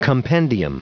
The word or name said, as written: compendium